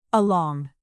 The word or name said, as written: along